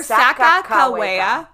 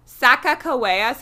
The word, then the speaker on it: Sacagawea: So either Sacagawea. Sacagawea.